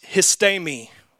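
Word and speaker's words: histemi (ἵστημι): But the word is, it's pronounced histemi.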